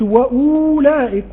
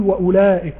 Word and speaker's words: وأولئك: فأقول ما أقولش وأولئك وأولئك، بقول وأولئك وأولئك هم المفلحون وأولئك هم المفلحون. الواو دي لا تنطق أبدا لا وصلا ولا وقفا.